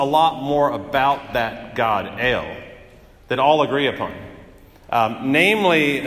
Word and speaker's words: El: That can only tell us up to the point of the word God, which in Hebrew is El, E-L, El, it's pronounced El.